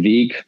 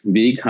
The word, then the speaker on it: Weg: dass äh, dann auch der Weg Weg heißt und Alexa nicht weg sagt beispielsweise.